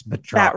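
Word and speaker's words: Batroc: It's spelled Batrock. It's Batrock. – Batrock. Okay. Not the bat from, that's Bartok. Okay.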